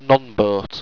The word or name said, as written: nonbote